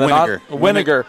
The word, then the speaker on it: Weiniger: Alec Weiniger, I hope I'm saying his name right. – And Winiger. Winiger like vinegar.